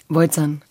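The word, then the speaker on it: Bojcan: Und jetzt, wenig später, sitze ich vor dem Mann, äh, dessen Name ich so hin und her spiele, Alexander Beutzern. Klingt so ein bisschen wie aus meiner Ecke, Beutzern. – Boah, toll, du bist die erste äh, Person, die das richtig ausgesprochen hat. – Beutzern.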